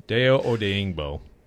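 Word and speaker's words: Odeyingbo: Deo Odingbo. That's correct. – That is correct.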